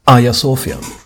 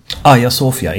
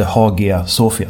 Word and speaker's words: Hagia Sofia: Hagia Sofia. Hagia Sofia, inte Hagia Sofia som det skrivs.